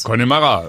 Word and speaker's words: Connemara: Connemara.